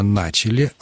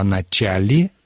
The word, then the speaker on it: начали: Нужно говорить именно «начали», а «начали» исключено. Такое ударение просто исключено.